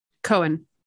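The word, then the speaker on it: Coughlan: How do you spell it? – Cohen. C-O-U-G-H-A-N. – Cohen. – Okay. Yep.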